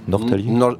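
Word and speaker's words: Norrtälje: Alors là, vous allez évidemment me corriger sur la prononciation à Nortalie. – Nortalie.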